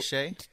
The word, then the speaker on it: Hsieh: Yeah, spelled H-S-I-E-H, but I'm going to go with Tony Shea because I was going to butcher it and you just told me how to say it.